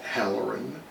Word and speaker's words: Hallerin: I'm on Twitter at Halloran. My name is spelled H-A-L-L-E-R-I-N.